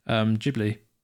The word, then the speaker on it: Ghibli: No, I had a look, and the proper pronunciation from the studio is Ghibli.